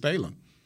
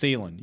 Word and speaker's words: Thielen: But the problem you have is Thielen. – Right. – Did I say his name right? – Yeah. – Yes. – Thielen.